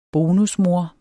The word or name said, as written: bonusmor